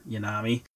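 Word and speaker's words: Yonomi: Y-O-N-O-M-I. I think that's how you say it, is Yonami.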